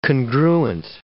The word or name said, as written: congruent